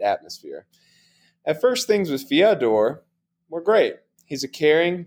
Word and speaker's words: Fyodor: Bo, can you check me on that pronunciation? I know you're fluent in Russian. – Yeah, Fyodor. – Fyodor, okay. Fyodor. – No. Fyodor. – Fyodor. – Yep.